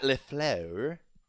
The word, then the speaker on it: LaFleur: Matt LaFleur. That's how I pronounce it, if you want the correct French-based pronunciation.